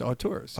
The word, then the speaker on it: auteurs: A-U-T-E? A-U-T-E-U-R-S. Auteurs. – Auteurs. Yeah. Good. – Auteurs of Delco. – It's a little highfalutin for Delco. – It is. That's true. – I think you're pronouncing it correctly.